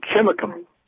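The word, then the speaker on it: Chimacum: It's Chimicum. – Chimicum.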